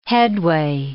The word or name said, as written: headway